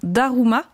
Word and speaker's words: Daruma: Ça s'appelle un Daruma. Je prononce bien ? – Tout à fait, tout à fait.